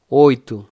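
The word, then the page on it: 8: Portuguese; Brazilian Portuguese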